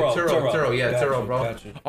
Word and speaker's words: Turo: How do you spell that? – T-U-R-O. – Oh, Toro. Turo.